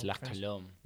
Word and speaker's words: La Colombe: Is it really pronounced La Colombe? – Yeah. – Yes. – The one with the E at the end. – Yes. – You don't pronounce the E? – It's nope. French, man. – It's French. – It's La Colombe.